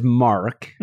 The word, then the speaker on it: Mark: So what I don't get about you people is, so my name is Mark, M-A-R-K. I find most Australians refuse to pronounce the R in my name. It's Mac.